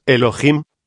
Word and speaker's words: Elohim: Elohim, en realidad de verdad, es una palabra femenina con un final masculino pluralizado, la H, tiene sonido, se pronuncia como J, de manera que su pronunciación exacta es Elohim.